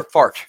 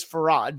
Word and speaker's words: Farhad: Farhad Muhammad, you know, I think you is once, it Farhad or Fart? – No, it's Fard is Fard. How it's spelled, but it's Farhad because I've known him for many years.